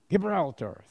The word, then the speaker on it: Gibraltar: Tarshish, a little bit west of the rock of I have a problem pronouncing that word, Gibraltar. Gibraltar. Thank you, sister.